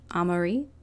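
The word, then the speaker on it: Amaury: Also, real quick, I realized I think I mispronounced his name. It's Chef Amari, because he's French.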